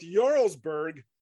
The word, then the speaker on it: Jarlsberg: And I want to point it out, not only because of that, but because they pronounce it correctly with Jarlsberg here, as opposed to the Jarls saga.